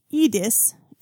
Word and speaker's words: Iris: And fourth, Iris is also not the same as the English female name Iris. Despite it often being romanized as such, it is pronounced more like Edis.